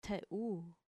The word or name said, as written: theu